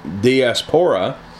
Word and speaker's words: diaspora: The meaning of dispersion comes from the Greek word, which is pronounced diaspora, and it means to sow or to scatter like seed.